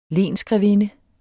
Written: lensgrevinde

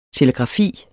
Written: telegrafi

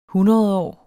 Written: hundredårs

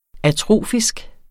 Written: atrofisk

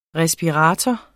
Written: RESPIRATOR

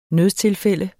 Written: nødstilfælde

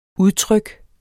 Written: udtryk

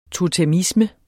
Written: totemisme

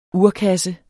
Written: urkasse

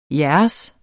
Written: jeres

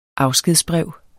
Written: afskedsbrev

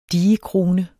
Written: digekrone

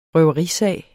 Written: røverisag

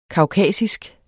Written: kaukasisk

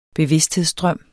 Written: bevidsthedsstrøm

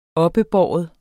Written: oppebære

